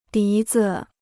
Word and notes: 笛子: dí zi

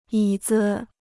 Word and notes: 椅子: yǐ zi

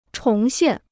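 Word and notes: 重申: chóng shēn

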